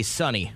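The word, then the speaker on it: Sonny: He would say Sunny. – Rhymes with Sunny. – Yes. – Is that what you said? You said funny. – That's what I said. – Did you say Sunny? – Sunny and Cher, let's do this.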